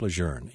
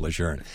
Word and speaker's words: Lejeune: I should point out that it is our policy here at the request of the Marines and the officials at Camp Lejeune to pronounce it Camp Lejeune. You are free to pronounce it the way you want. I'm sure you do it the way it's most comfortable for me and the way I pronounced it all my life, but I am nothing if not obedient. And so you will hear me say Camp Lejeune.